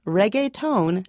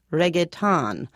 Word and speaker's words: reggaeton: Correct pronunciation is reggaeton, not reggaeton.